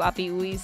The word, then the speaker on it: Api Uiz: That's spelled A-P-I-U-I-Z. I'm pretty sure we pronounce that Api Uiz.